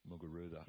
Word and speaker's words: Muguruza: Muguruza.